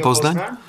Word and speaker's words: Poznań: Czy mógłbyś na koniec nagrać mi jeszcze jedno zdanie, jak mówisz o słuchaniu Radia Poznań?